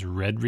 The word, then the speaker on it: read: which is to use the long E in both words and say read receipts, because red receipts is a little bit rural juror.